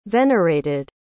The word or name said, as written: venerated